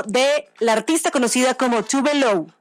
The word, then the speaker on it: Tove Lo: Se llama Tove Lo, o sea, Tove Lo es la pronunciación, pero su nombre real es Eva Tove Elsa Nilsson.